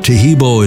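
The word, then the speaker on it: Taheebo: Tahibo is spelled T-A-H-E-E-B, like boy, O, then the word tea, and then the word club.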